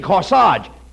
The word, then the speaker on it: corsage: You mean corsage. A-G-E is pronounced Arge, as in corsage and garage.